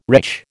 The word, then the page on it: retch: American English